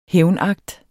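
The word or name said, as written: hævnakt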